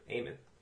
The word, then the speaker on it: Eamon: E-A-M-O-N? – What's the first name? – Eamon. – Like Eamon. – Like Amen? Like, spelled like that? – No, like Eamon de Valera from the IRA. Okay. – Like A-E? – That's perfect. E-A-M-O-N.